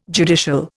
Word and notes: judicial: Indian

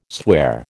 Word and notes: sware: American English

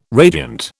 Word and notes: radiant: British English

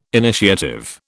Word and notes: initiative: British English

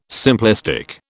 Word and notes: simplistic: British English

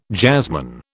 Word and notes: jazzman: British English

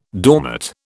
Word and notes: doormat: British English

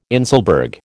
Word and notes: inselberg: American English

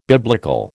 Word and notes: biblical: American English

